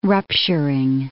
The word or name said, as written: rupturing